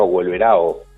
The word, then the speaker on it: Wolverhampton: Bueno, el Wolverhampton, manejado por capitales chinos, pero también con gran influencia de Jorge Méndez, del empresario portugués, por eso también se lo llama Wolverao, ¿no? Como la pronunciación portuguesa.